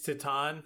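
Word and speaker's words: Titane: So, Titan. Yeah, It's it's it's tight. It's Titan. it's Titan. – Titan. – Titan. – shut up. I don't Titan. care. Titan. – Titan. – Titan. Titan. – Well, that's how they say it in France. They don't want me to make fun of them. It's a French word.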